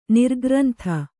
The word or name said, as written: ನಿರ್ಗ್ರಂಥ